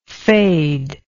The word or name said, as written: fadedness